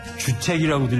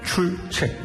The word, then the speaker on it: 출첵: 선생님, 저 틀린 사이로 출첵이라고 발음하신 것 같은데, 주책이라고 들리거든요? – 출첵.